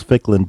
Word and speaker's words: Ficklin: And my name, I only have two syllables, Ficklin, but even that gets mutilated, so I can understand.